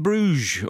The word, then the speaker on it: Brugge: Club Bruges, or Club Brugge, as it's actually pronounced, Andy.